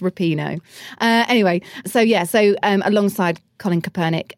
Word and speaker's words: Kaepernick: and that you know goes alongside with Colin Kaepernick.